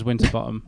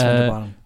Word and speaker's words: Winterbotham: Group Captain Winterbotham, which I'm going to pronounce as Winterbottom, I'm sure. – Yeah, it's Winterbottom.